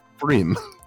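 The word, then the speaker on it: pFriem: It's lowercase P, capital F, R-I-E-M. Mm. It's pronounced Freem. Freem.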